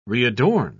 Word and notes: readorn: British English

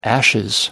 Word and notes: ashes: British English

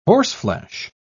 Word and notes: horseflesh: British English

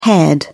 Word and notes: haired: British English

